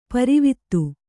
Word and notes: ಪಾರಾವತ: Kannada

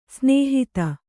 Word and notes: ಸ್ನೇಹಿತ: snēhita

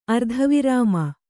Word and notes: ಅರ್ಧವಿರಾಮ: ardhavirāma

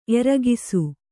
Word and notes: ಎರಗಿಸು: Kannada

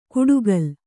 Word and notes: ಕುಡುಗಲ್: kuḍugal

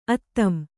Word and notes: ಆದಂ: ādam

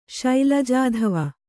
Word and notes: ಶೈಲಜಾಧವ: Kannada

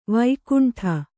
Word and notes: ವೈಕುಂಠಿ: vaikuṇṭhi

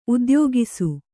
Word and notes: ಉದ್ಯೋಗಿಸು: udyōgisu

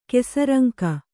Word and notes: ಕೆಸರಂಕ: Kannada; kesaraŋka

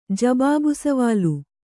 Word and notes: ಜಬಾಬುಸವಾಲು: jabābu savālu

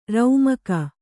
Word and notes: ರೌಮಕ: raumaka

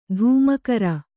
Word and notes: ಧೂಮಕರ: Kannada

